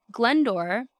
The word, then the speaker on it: Glyndwr: Glendore, I listened to the pronunciation of this, guess how this is spelled? G-L-Y-N-D-W-R. Now we have no vowels.